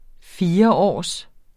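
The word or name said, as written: FIRÅRS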